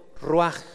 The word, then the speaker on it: ruaj: La palabra espíritu, hermano, viene del hebreo ruaj. Ruaj, R-U-A-J con acento en la U, ruaj.